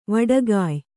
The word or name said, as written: ವಡಗಯ್